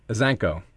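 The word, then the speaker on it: Isaenko: Azenko.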